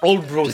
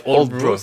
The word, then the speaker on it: Aldeburgh: Ça s'appelle Oldbro, ça s'écrit Aldeburgh, mais ça se prononce Oldbro,